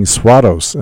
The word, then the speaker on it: Swados: It was called Runaways, and Elizabeth, and her name is spelled S-W-A-D-O-S. I've been saying Swados, and I think a friend of mine came up recently and said, I knew her, and her name is Swados, or something to that effect.